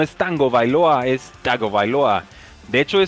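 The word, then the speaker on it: Tagovailoa: Es Tango Bailoa. Entonces, si soy no, raro, créanme, está estudiado. Tango Bailoa. – Él, mismo lo ha dicho, que así se pronuncia.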